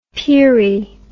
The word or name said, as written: peerie